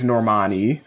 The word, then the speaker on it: Normani: I found out I've been saying it wrong for like 3 years. – But not Normani. – It's Normani, apparently.